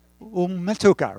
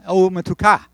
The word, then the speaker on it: Umetuka: It's on the first syllable there, right? Oh, no, last syllable. Umetuka. Umetuka. Okay. Everybody got that? Accents in Hebrew are almost always on the last syllable. So, umetuka.